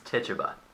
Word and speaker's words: Tituba: I believe it's pronounced Tituba.